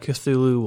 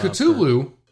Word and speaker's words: Cthulhu: I can only assume that Cthulhu will some point show up. – Cthulhu.